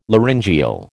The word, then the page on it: laryngeal: American English